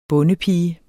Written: bondepige

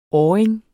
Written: åring

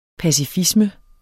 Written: pacifisme

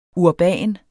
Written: urban

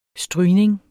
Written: strygning

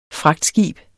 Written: fragtskib

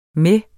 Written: med

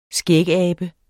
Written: skægabe

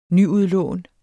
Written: nyudlån

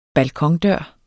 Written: BALKONEN

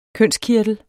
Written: kønskirtel

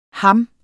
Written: ham

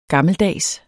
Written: gammeldags

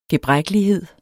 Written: gebrækkelighed

